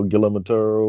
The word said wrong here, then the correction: Guillermo del Toro: Guillermo del Toro.